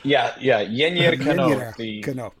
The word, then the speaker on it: Yennier Cano: Yennier Cano. Did I pronounce that right? – Yennier Cano. – That sounds way better than what I told you. So I think you did good.